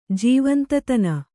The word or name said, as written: ಜೀವಂತತನ